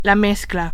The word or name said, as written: mezcla